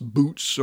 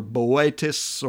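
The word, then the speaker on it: Boötes: In the same house of Virgo, another constellation called, it can be pronounced Boots or Boetes or B-O-O-T-E-S.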